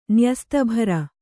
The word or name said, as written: ನ್ಯಸ್ತಭರ